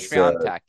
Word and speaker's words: Świątek: I mean, just looking at these names on paper, Iga Świątek, I think we're saying the name wrong, has Świątek, yeah. – yeah, there you go.